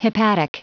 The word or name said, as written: hepatic